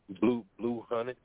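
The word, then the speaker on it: Bleu Hunnitz: Blue Hunted.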